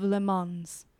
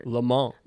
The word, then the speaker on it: Le Mans: It looks like Le Mans. Oh, you don't pronounce the S.